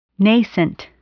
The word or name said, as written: nascent